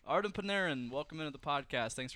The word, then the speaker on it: Artemi Panarin: Artem Panarin.